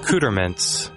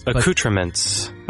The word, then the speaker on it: accoutrements: I mean, that is the correct English pronunciation. – Accouterment? – I would never say that. – Accouterment. It's a mint for your cooter. – Yeah. – How about accouterments? – Accouterments.